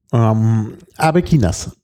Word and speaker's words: Arbequinas: Aber Aguinas oder so? – Ich kann nicht alles gleichzeitig machen. Also essen und sprechen. – Sprechen ist keine gute Kombination. Arbequinas.